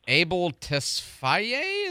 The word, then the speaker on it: Abel Tesfaye: The weekend is ditching his long-standing, super popular, well-known brand to become Abel Tesfaye.